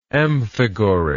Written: amphigoric

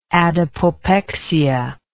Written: adipopexic

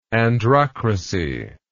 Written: androcratic

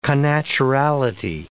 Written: connaturality

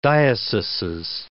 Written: dioceses

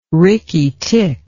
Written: rickytick

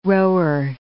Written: rower

